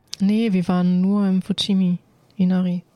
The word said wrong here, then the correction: Fushimi Inari: Ah, ja, also ich habe das bestimmt falsch ausgesprochen, aber. Fushimi Inari.